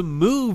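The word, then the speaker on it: Mu: I didn't even know how to say it last week. The Moo variant.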